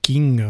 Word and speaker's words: Qing: Ça a donné naissance à un livre qui s'appelle donc Yi Qing, Yi changement, Yi ou mutation, transformation, et Qing, ou qui se prononce King maintenant, qui veut dire classique livre.